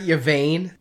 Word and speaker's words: Yvaine: Am I pronouncing this right? Yvaine? – Yeah. – yeah. Okay. Because vain would be too obvious.